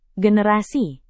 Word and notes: generasi: Indonesian

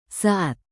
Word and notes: saat: indonesian

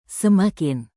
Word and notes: semakin: indonesian

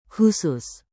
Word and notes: khusus: Indonesian

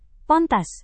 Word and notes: pantas: Indonesian

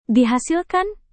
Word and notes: dihasilkan: indonesian

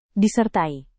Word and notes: disertai: indonesian

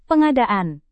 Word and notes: pengadaan: Indonesian

